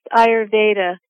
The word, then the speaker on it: Ayurveda: It's pronounced Ayurveda.